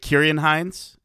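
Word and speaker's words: Ciarán Hinds: I do also think that, is it Kieran Hines? Is that how I'd pronounce that? – Yes, it's a hard C.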